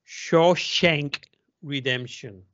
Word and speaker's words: Shawshank Redemption: Shawshank Redemption.